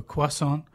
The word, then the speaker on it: croissant: An example of that, this morning I went to pick up a croissant and a cup of tea.